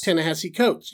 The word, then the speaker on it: Ta-Nehisi Coates: Ta-Nehisi Coates? – Ta-Nehisi Coates.